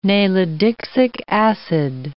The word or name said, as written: nalidixic acid